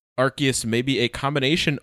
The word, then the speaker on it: Arceus: Because this for me, like, any time in the past that you've said, it's Arceus because this is how you, like, pronounce the name and this is what...